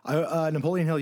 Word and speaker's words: Napoleon Hill: I feel like you're a good person for this because you were like a Napoleon Hill guy for a long time. Maybe you still are. Did I say the name right? – Napoleon Hill, yes.